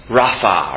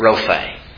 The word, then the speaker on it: Rapha: Or Jehovah Rapha, or some pronounce it Rophe, the Lord our healer.